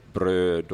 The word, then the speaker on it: bröd: Alltså du tar bröd och röd och död och så vidare. Så göteborgskan, liksom i många andra dialekter, så heter det brö och dö.